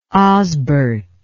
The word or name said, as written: osbert